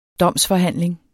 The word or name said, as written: DOMSFORHANDLINGERNE